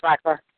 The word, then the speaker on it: Bacharach: their assignment to sing songs composed by Burt Bacharach. Is that how you pronounce it? – Yeah, Burt Bacharach.